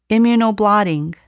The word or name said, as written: immunoblotting